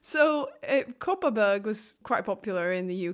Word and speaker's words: Kopparberg: But the thing is, whenever I ordered it and I pronounced it in Swedish, the way it sounds, I say, could I have a Kopparberg, please? The people working in the restaurants and bars wouldn't understand what I meant. So I had to pronounce it the way it's pronounced in the UK.